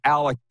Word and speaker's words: Alek: So we had somebody in the Blue Jays office watching the show yesterday, and they wrote in and they're mad that I said Alex Manoah. I said both Alek and Alex, but you're right. So I'll put it on me. I apologize. I said your pitcher's name wrong.